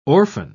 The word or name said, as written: orphan